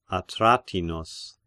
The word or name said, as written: Atratinus